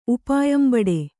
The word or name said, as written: ಉಪಾಯಂಬಡೆ